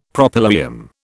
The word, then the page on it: Propylaeum: British English